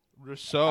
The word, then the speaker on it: Rousseau: Gregory Rosial, defensive end. – Rousseau.